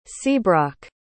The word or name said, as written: seabroc